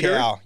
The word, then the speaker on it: Nikau: And they really did build an intimidating forward pack, I would say, between him, Lazarus, and their Papua New Guinean, is it Nikau? Am I pronouncing that right? – Nikau, yep.